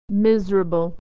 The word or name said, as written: miserable